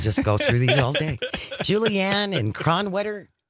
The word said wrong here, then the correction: Kronenwetter: Cronenwetter. – That doesn't feel like a place. – Cronenwetter. – Spell that for me. – K-R-O-N-E-N-W-E-T-T-E-R. – It's a village in Wisconsin.